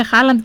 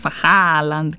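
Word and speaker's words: Haaland: Εμείς το λέμε Χάλαντ και φροντίζουμε να ακουστούν και τα δύο Α. Χάλαντ.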